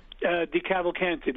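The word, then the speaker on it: DeCavalcante: De Cavalcante, the real Sopranos.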